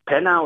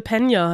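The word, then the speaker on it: Peña: Dann ist auch eine andere Figur dabei, dieser Louis, der, ich weiß gar nicht, wie der Schauspieler genannt wird, Michael Penner oder wie spricht man das aus? – Michael Pena heißt der.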